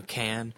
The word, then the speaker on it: Cannes: People pronounce it a couple different ways. I call it, you know, Cannes.